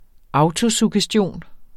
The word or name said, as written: autosuggestion